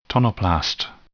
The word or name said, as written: tonoplast